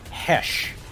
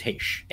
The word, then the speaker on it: Heche: It has never been Heche. Ever mispronounced that way by anyone ever. It is Heche. Anne Heche.